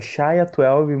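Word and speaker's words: X Æ A-12: E, e se pronuncia Shia 12 Musk e,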